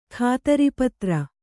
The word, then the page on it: ಖಾತರಿಪತ್ರ: khātaripatra